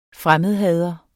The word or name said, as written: fremmedhader